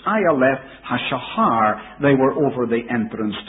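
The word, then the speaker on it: Ayaleth Hashahar (אַיֶּלֶת הַשַּׁחַר): So Ayaleth Hashahar was the way that he pronounced them.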